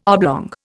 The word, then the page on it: oblong: Indian